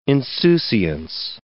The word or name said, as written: insouciance